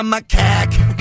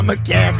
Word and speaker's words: macaque: If I can't sleep, or I have writer's block, I don't normally like monkeys, but I like to play with the macaque. I said it right. Play with the macaque. I got it right the last time.